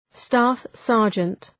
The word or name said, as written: staff-sergeant